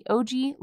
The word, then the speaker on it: OJI: Most recently, he co-founded the OG Life Lab, which I thought stood for Original Gangster Life Lab, but came to find out it's spelled OJI Life Lab, which is a corporate learning firm that develops innovative digital learning systems on emotional intelligence.